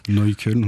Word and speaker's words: Neukölln: Oui, j'ai habité à Neukölln quand je suis arrivé. – Vous prononcez mieux que moi. – Neukölln, on prononce.